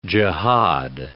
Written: jihad

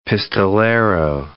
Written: pistolero

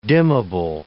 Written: dimmable

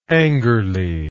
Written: angerly

